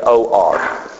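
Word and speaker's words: or (אור): This is the word light, or it's pronounced O-W-R, or you can actually spell it O-U-R, or. Just sort of like saying in our English, just say O-R. It's the same thing, or.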